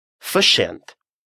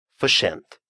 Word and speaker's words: för sent: Verschent. Слишком поздно. Verschent. Обратите внимание на следующий момент. Буквосочетание «рс» в шведском языке дает звук «ш».